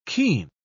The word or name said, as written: keen